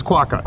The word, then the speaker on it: quokka: How do you pronounce this? Is this quokka? – I believe it's quokka. – Quokka? – Yep.